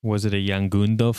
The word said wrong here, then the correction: Yongnuo: Yongnuo. Pronounce it right.